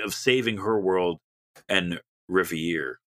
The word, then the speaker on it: Reverie: It's a game starring Polly, an upbeat young woman who can travel between our world and Revere. Revere? I don't know how to say it. It's a French word. Reverie. – Reverie, thank you, Chris.